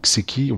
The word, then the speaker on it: Seki: Juste pour expliquer, voilà, c'est qui, c'est un prénom, ça s'écrit S-E-K-I. Donc, c'est qui, on pourrait dire c'est qui, virgule, ouais.